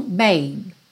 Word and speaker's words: babe: Então, em inglês, tanto tem o baby, com Y, quanto babe, babe, que é B-A-B-E, mas nesse caso o E final não é pronunciado, então você tem esse som babe, babe, ok?